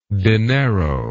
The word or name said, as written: dinero